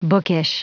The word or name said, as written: bookish